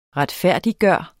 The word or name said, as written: retfærdiggøre